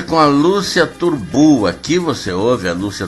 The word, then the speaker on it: Turnbull: E esse Turbu se escreve T-U-R-N-B-U-L-L.